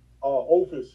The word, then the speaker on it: Opus: And how do you say it? Orpheus? Is that how you say that? – Opus, yes.